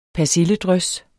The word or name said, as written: persilledrys